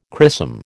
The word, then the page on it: chrisom: American English